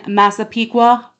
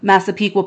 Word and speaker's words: Massapequa: He lives in Massapequa Park. I'll say that properly because people from here would say Massapequa Park, right? That's how you say that if you're from here.